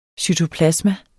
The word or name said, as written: cytoplasma